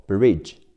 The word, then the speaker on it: bridge: between between 短语在什么和什么之间？between and between and 名词桥 bridge b r i d g e bridge r i 读 r i r 加上 b bri d g 组合发 z 的音，结尾的 e 不发音，所以 bridge bridge 名词小船 boat b o a t boat o a 字母组合发 o 的音，加上 b b o bo，加上 t boat boat。